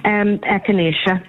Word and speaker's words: echinacea: Echinacea.